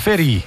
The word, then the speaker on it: Ferì: Come si legge esattamente la cefa? – Ferì. – Ferì. – Si ferì. – Che sono le ferite? – Ferito. – Ferito? Ah, ferì, è vero, giusto.